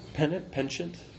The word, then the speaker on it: penchant: Penchant. – How do you say it? – Yeah, penchant. Yes, but in English it's just penchant.